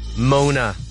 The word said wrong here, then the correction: Moana: You do get an extra mark if you can point out that I pronounced Moana wrong.